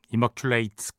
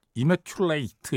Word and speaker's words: Immaculate: 저는 Immaculate 이렇게 발음되는 줄 알았더니 Immaculate이군요. Immaculate.